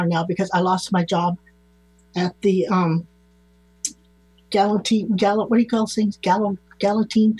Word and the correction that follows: guillotine: thing? – The guillotine? – Thank you. The guillotine.